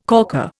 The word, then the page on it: corker: Indian